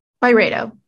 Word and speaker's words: Byredo: How do you say it? Byredo? – Byredo.